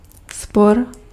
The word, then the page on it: querelle: France; [yn kə.ʁɛl]